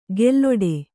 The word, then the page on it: ಗೆಲ್ಲೊಡೆ: gelloḍe